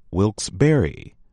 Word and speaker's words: Wilkes-Barre: It is not Wilkes-Barre, but rather Wilkes-Barre. Do not drop the last E, as common sense and French speakers would suggest.